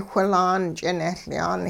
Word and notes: whilaan ch'ineLyaan: hupa

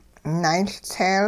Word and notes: naayLtseel: hupa

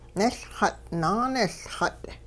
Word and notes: niLxut' naaniLxut': Hupa

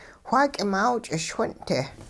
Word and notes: wha k'imaaw ch'ischwin'-te: hupa

